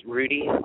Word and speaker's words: Rudie: This is Rudy calling from Brooklyn, New York. Calling to say that last week's episode, Jack mispronounced my name. It's Rudy, R-U-D-I-E, not Riddy, as you called me, but it's all good.